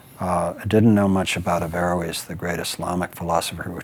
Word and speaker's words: Averroes: I'm still having trouble with the pronunciation because I keep saying Averroes, but it's Averroes. – Averroes. I just get the, and I need to leave out the N, Averroes.